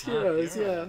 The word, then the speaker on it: gyros: This is G-Y-R-O-S. I'm just learning now it's not pronounced Gyros. Gyros. No, I was thinking it was Gyros with a soft J. – No, it's Heroes, Anyway, Walker walks in looking beautiful and baby blue.